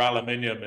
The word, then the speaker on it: aluminium: I want to know about like, what, how do you say aluminum? – Aluminium. It has an extra I in it.